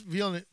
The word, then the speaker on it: Villeneuve: And I think Denis Villeneuve, I can't remember how to pronounce his last name. – Villeneuve? – There you go.